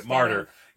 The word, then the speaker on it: Marder: It's spelled like martyr, like murder, but with an A. – Okay, martyr.